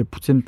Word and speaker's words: poutine: puis c'est des parfois, je vais le voir inscrit P-O-U-T-E-E-N, comme teenager, teen, poutine.